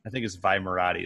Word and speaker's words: Vi Moradi: I think it's Vi Moradi is how they say it.